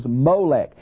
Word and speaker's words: Molech: Now, Milcom seems to be a corruption of the Hebrew root M-L-K, king, Melech. But sometimes in the Bible it's pronounced Milcom and sometimes Molech.